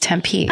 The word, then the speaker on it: Tempe: He says the way you pronounce it is Tempe.